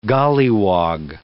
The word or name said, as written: golliwog